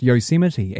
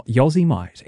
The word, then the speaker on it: Yosemite: I was about 25 years old when I realized very publicly, I was actually talking on the radio, when I learned that the word Yosemite is pronounced Yosemite and not Yosemite.